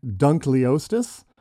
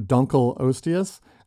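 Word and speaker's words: Dunkleosteus: The Dunkelosteus, and l- like all good scientific names, there is ongoing debate about how to pronounce this one. – Of Some course. people prefer to say hmm. Dunkleostis uh, instead of Dunkelosteus because I think Dunk Lee Ostia sounds more sort of it's British, like isn't it?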